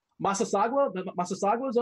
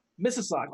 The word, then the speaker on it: Mississauga: Massasauga's own? Is that correct? – Mississauga.